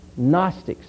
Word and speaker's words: Gnostics: Gnostics. It's spelled G-N-O-S-T-I-C-S. Gnostics.